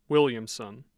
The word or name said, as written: Williamson